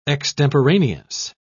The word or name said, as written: extemporaneous